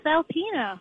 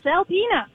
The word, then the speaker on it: Alpena: So the name is Alpena. Yes! I grew up near there. It's Alpena.